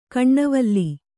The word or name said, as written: ಕೆನೆವಾಲ್